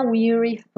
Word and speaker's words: weary: Essa pronúncia é de I. Weary. Não é a do verbo to wear que é aberta.